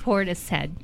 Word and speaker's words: Portishead: Portishead.